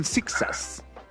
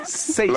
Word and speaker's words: Seijas: Aunque aquí hay muchos que le dicen Zixas. Y yo les dije Noxas. No sé. – Noxas. – Es Seijas. – Lo que pasa es que en ese tema de las pronunciaciones, pues todo depende de cómo se haga, porque si uno lo está haciendo en español, perfectamente puede decir Seijas sin problema. – Pero es que además en francés también se pronuncia Seijas.